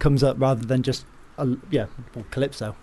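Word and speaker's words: Kylypso: Calypso. – Hello. – Am I pronouncing that correctly? – It is indeed, yes.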